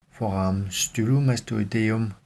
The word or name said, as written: foramen stylomastoideum